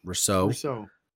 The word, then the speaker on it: Rousseau: You have Greg Rousseau. I always mispronounce that name. – Rousseau.